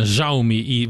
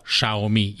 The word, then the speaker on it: Xiaomi: tehát ilyen Xiaomi, valahogy így mondják ki, ez baromi nehéz, tehát a nemzetközi standard, amit a cég szeretne, az a Xiaomi, így kell kiejteni.